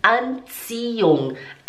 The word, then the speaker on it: Anziehung: Agora o último, die Anziehung. Anziehung seria a atração Olha só, aqui agora o H ele não é pronunciado. Aqui você fala antion, de antion.